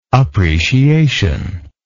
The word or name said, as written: appreciational